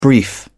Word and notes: brief: British English

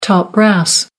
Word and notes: top brass: American English

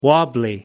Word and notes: wobbly: American English

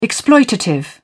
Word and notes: exploitative: British English

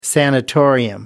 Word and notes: sanatorium: American English

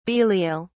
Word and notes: belial: British English

